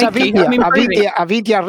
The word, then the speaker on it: Avintia: So I pronounce it Avinci Racing, but I think Manuel's pronunciation was closer to mine than say? – You are fantastic. Your French isn't that good. The Spanish is okay. – That's good. – It's Avinci Racing.